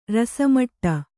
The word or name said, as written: ರಸಮಟ್ಟ